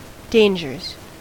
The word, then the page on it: dangers: American English